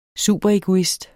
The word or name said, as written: egoist